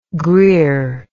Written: Greer

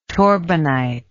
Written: torbanite

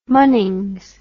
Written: munnings

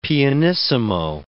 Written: pianissimo

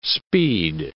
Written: speed

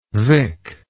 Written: Vick

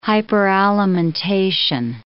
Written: hyperalimentation

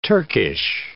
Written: Turkish

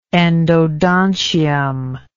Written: endodontium

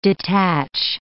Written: detach

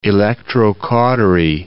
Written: electrocautery

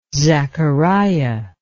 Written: zachariah

